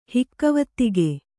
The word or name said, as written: ಹಿಕ್ಕವತ್ತಿಗೆ